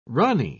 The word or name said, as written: rani